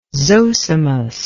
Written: zosimus

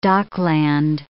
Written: dockland

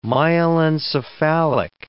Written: myelencephalic

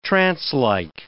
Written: trancelike

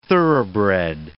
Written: thoroughbred

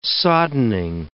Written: soddening

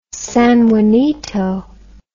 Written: sanjuanito

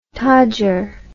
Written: todger

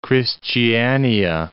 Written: Kristiania

